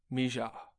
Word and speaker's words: Mija: It's M-I-J-A. – It would be Miha if she was Spanish. – Hmm. Mija. – Yeah. – Like that? – Yeah, Mija. – Because it's not a hard J.